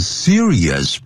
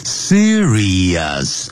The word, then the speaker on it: serious: 那么 some of these substances 有些这些物质呢 can cause serious problems，会引起呢严重的问题啊。 serious 啊，不要念成 serious，常常听了这我们中国人常常念成 serious 啊， serious 啊， serious，第二念 serious，就像收音机叫 radio，不要念成 radio， radio， serious， serious， radio，啊，这样念 radio，像影像呢，视频叫 video， video，不要 video 啊， video， radio， serious， serious problems to humans，对人类啦， plants。